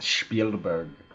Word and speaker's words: Spielberg: It's pronounced Spielberg.